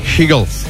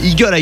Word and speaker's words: Hegel: Alors, faut bien prononcer Hegel. Parce que si tu dis Eagle, I cherry, hein, c'est genre, t'as l'impression que tu parles un mec qui parle pas.